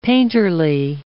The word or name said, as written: painterly